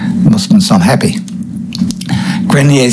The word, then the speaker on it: Grenier: Famed archaeologist Robert Grenier, who discovered the San Juan in Labrador, dead at 88. Now, I pronounced his name Grenier, it's spelled G-R-E-N-I-E-R.